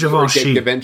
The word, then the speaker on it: Givenchy: Although the costumes for Audrey Hepburn were Pierre Gavinci, which makes total sense. Mm-hmm. I don't know if I pronounced that right. Is it Gavinci? – I thought it was Givenchy.